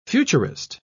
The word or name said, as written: futurist